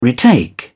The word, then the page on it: retake: British English